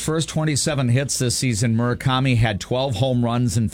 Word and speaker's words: Murakami: Yeah, I don't know if his first name is Munetaka, but I believe his last name is pronounced Murakami. – Murakami.